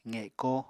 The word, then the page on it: qeko: apatani